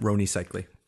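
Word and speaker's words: Rony Seikaly: How do you pronounce it? – Roni Cicely. Tony. Roni Cicely from the Miami Heat. – Roni Cicely. – Whoops. Miami Heat. – So it's not Tony.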